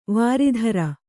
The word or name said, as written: ವರುತ್ರ